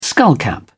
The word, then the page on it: skullcap: British English